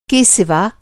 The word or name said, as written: सिवा